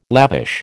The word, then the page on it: lavish: American English